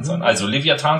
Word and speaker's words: Leviathan: Es geht nämlich äh, um das äh, Lied von der Band Vollbeat, und zwar das äh, Lied Leviathan. Ich spreche das bewusst deutsch aus. Wird genauso natürlich der englische Titel auch geschrieben, aber Leviathan halt ausgesprochen.